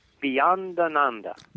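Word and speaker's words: Beyondananda: Beyond Ananda. – Beyond Ananda. – Ananda. Ananda. A lot all the Swamis have Ananda after their name, and I found out that Ananda means bliss.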